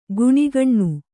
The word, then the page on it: ಕುಣಿಗಣ್ಣು: kuṇigaṇṇu